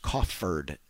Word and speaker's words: coffered: So anyway, but it's properly pronounced coffered ceiling.